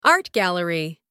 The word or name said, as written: art gallery